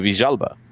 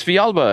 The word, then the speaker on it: Villalba: I always said Tito Villalba. And people were like, oh, why are you saying that? It's Villalba. And I'm like, no, he's from Argentina. That's how they say it.